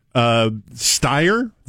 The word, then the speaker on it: Steyer: Now, a bit of a plot twist is that Steyer, is that how you pronounce his last yes.